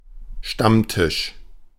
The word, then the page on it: Stammtisch: /ˈʃtamtɪʃ/